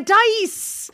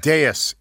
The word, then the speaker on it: dais: A dice!